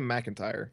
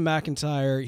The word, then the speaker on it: McIntyre: Liam McIntyre. – All right, Liam McIntyre.